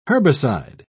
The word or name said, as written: herbicide